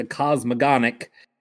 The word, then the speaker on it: cosmogonic: Am I even pronouncing that close to correct? – Well, you're close to correct. I mean, I only know because I listen audiobook instead of reading it, but it's like the cosmogonic cycle.